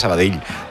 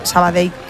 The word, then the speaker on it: Sabadell: El cachondo de Kiki Lesa dice, pronuncias muy bien Sabadell. Mis respetos. – Sabadell. A mí me lo decían mucho cuando estaba en Primera Federación, porque yo también decía Sabadell, que es como se dice.